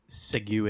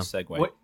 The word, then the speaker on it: segue: We tried to segue into that. – Yeah. – Segway. – What did you think? – Segway. – It's a French term. It's segue.